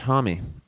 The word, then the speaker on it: Homme: It is pronounced Josh Homme.